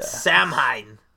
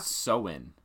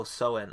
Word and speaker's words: Samhain: Samhain. Sorry to all my Irish friends out there if I couldn't pronounce that right, but mostly in the area. – Samhain. – Oh, Samhain.